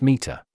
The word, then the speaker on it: METR: Arc Evals is now METR, Model Evaluation and Threat Research, pronounced META.